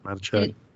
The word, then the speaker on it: Matichak: Um, I really liked Andi Matichak. Is that how you say her name? – Yeah, Matichak.